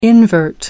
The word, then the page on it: invert: American English